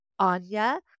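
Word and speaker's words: Áine: But the ones that are great to work with for the summer solstice specifically, there's the Celtic goddess Aine, A-I-N-E, and I believe it's pronounced Aine.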